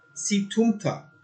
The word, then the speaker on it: Situmta: So Rav Papi says in the name of Rava, Hai Situmata, Situmta. That's how I've heard it pronounced.